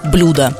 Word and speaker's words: блюдо: Вы совершенно правильно сакцентировали внимание на неправильной постановке ударения, потому что благо и блюдо. Поэтому то, что вас так сильно покоробило, коробит, конечно же, и нас. Поэтому нужно запомнить благо блюдо.